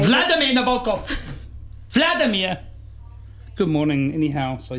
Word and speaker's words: Nabokov: Good on you, Nabokov. You're a real brain box. Boom. – You pronounced his name correctly.